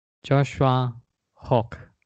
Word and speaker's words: Joshua Hoch: Gratitude Seeker, welcome to this special episode with the Circle of Joy Award winner, Joshua Hoch.